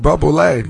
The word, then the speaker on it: Bublé: Bublé.